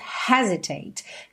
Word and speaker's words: hesitate: Essa palavra, inclusive, tem a sílaba tônica na primeira sílaba, don't hesitate. Na música, acaba não acontecendo isso por conta da métrica,